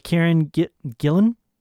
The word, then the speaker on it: Karen Gillan: Karen Gillan.